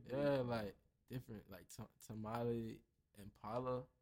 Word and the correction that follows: Tame Impala: you feel me? Like, Tame Impala? – Yeah, I can't even really pronounce it. That. – Yeah, bro, Tame Impala.